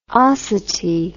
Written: osity

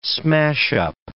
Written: smashup

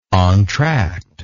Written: entracte

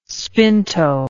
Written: spinto

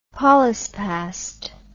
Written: polyspast

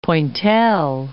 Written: pointelle

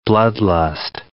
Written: bloodlust

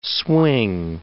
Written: swing

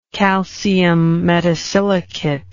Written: calcium metasilicate

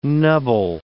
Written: nubble

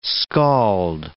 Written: skald

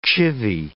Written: chivy

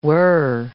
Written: whir

